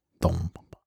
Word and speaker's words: Don Papa: Don Papa. Das muss ich mal richtig vormachen, weil das ja mal meinen Studenten beibringen will. Dass es assimiliert wird im Spanischen. Das N zu M vor P.